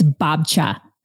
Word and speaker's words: Babcia: It's just the Polish word for grandma, and it's Babcia. Grandma. – It's Babcia.